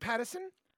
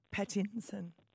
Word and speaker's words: Pattinson: Does he lose points because he said his name wrong? – Oh, did I? Is it Pattinson? – Pattinson. – Didn't I say Pattinson? – No.